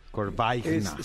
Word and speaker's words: Corbaigna: Es Corvaina. Corvaina. – Corvaigna. C-O-R-B-A-I-G-N-A. Corvaigna. ¿Ok? – Digo, nada más para que tú sepas y digas, ya tengan una clave entre ustedes, digan, ¿qué, mi amor? Corvaigna.